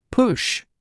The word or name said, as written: push